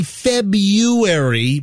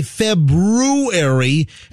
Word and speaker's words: February: Now, I say February, but some people say February, and it is spelled February. – It's February. The R is silent, I'm pretty sure.